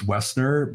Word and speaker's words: Woessner: My last name is Wessner, but it's spelled W-O-E-S-S-N-E-R.